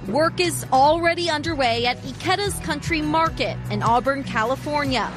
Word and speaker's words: Ikeda's: It's called Ikeda's, and it's pronounced exactly like the name of our colleague and friend, Emily Ikeda.